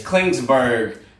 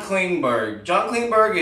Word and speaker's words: Klingberg: It's not Klingberg they say, it's Klingsberg. Klingsberg they want to trade, right? Klingsberg's, no one can get his name right.